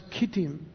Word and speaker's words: Kittim: In the King James it's spelled Chittim, but it's pronounced Kittim, which is the island of Cyprus, or the islands of Cyprus.